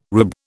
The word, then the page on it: rube: British English